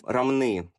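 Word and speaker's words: Ромны: Дальше они переместились в город Рамны, ударение на последний слог.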